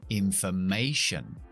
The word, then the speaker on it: information: Hemos dicho que acentuamos la penúltima sílaba de las palabras que terminan con T i O N, ¿te acuerdas? Entonces es information.